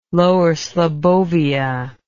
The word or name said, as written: Lower Slobbovia